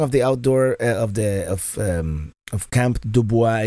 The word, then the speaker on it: Dubois: You would think, since it is a French name, that it would be pronounced Dubois. Mm-mm. Camp River Dubois, that's how they pronounce it, Missourians or Illinoisans.